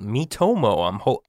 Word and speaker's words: Miitomo: Nintendo's launched a new app called Miitomo.